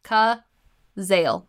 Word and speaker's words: Cazale: Cazale. Cazale. Like Zales, like the jewelry store.